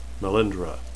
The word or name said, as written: melindhra